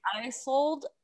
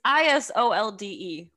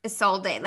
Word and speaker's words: Isolde: I know the mother's name, by the way, is Isold. It's Isolde. When I said it, literally has a lot of like names to it. I don't know. I don't know the words. – Yeah. Isolde.